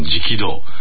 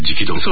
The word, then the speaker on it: 食堂: それにしてもあの薬師寺でね、再建された修行道との一つの直道、まあ、あの食堂と書いて直道、